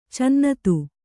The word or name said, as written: ಚಿನ್ನಿಧಿ